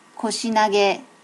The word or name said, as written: koshi nage